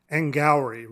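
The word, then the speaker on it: Angourie: Angowri. Rhymes with flowery.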